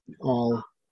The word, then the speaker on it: awl: That, what is it, AWL, how do you say that out? – All, yeah.